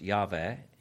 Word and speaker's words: Yahweh: Verses 4 through 6, God's personal name comes up, Yahweh or Jehovah, YHWH in Hebrew, and scholars believe that's pronounced Yahweh.